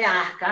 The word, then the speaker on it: ARCA: Um, um, ponto é sobre essa associação, é ARCA, é assim que se pronuncia mesmo, ARCA? – É, é, é ARCA, Associação de Resgate Histórico e Cultural dos Afrodescendentes.